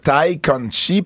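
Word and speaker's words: Tay Conti: Est-ce que tu prononces Ty Conti parce que tu sais la prononciation, parce que j'ai été marié à une Brésilienne, ou tu veux juste... Ou c'est juste une chance que tu prends de le prononcer comme ça? – C'est comme ça que ça se prononce.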